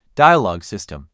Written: dialogue system

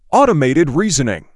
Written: automated reasoning

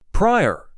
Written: Prior

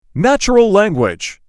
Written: natural language

